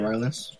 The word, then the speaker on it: Rylance: And then Mark Rylance... however you pronounce his last name. – Rylance.